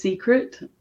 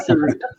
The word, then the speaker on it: Seerut: The worst I've heard was Seerut, and even worse than that was cigarette. So I'll take Seerut. It's better than being called a cigarette.